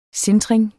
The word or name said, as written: sintring